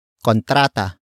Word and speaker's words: kontrata: Ang is the focus marker that introduces the main topic of the sentence, which is kontrata. Kontrata. Kontrata. Kontrata is spelled as K-O-N-T-R-A-T-A.